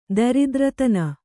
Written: ದರಿದ್ರತನ

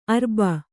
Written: ಅರಬ್ಬಿ

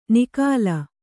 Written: ನಿಃಕಲ

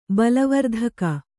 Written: ಬಲವರ್ಧಕ

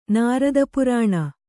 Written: ನಾರದಪುರಾಣ

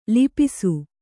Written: ಲಿಪಿಸು

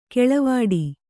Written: ಕಾಳವಟ್ಟ

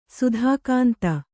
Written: ಸತುಗುಂದು

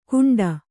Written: ಕುಂಡ

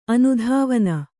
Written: ಅನುಧಾವನ

